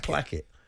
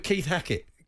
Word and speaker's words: placket: Is it a plaque or is it a placket? – I think placket. – Placket? Okay. As in, plaque. – Is it right to rhyme with Keith Hackett? – Keith Hackett. – That's right, okay.